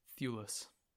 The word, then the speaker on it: Thewlis: I always pronounce his name weird. – Thewlis. – Sure. – Okay.